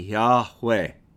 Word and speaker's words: Yahweh: If you were to pronounce it out, as close as I think we can get from the ancient Hebrew, it would be Yahweh. Y-A-W-E-A.